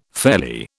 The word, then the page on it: fairly: British English